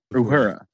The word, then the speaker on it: Uhura: And then there's also a weird scene where Uhura, how do I say her name? – Uhura.